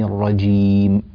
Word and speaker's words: الرجيم: بارك الله فيك أخي الكريم شكيب من الجزائر، والقراءة ممتازة، لكني يعني أشك قليلا في أنك قد أجدت نطق حرف الجيم. أعوذ بالله من الشيطان الرجيم، جيم، هذا صوت الجيم، لا تطلع معك من الشيطان الرجيم، الرجيم، هكذا الجيم ليست صوتا انفجاريا، فتنبه لهذا.